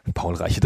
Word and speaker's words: Paul Reiche: So, und dann kommen wir auch schon zu dem dritten, nämlich Paul Ritchie III. Ha, wie ich das jetzt kann. Paul Reiche 3, ich lach mich tot. Hörst du, Gunnar, von 1995, so wird das ausgesprochen.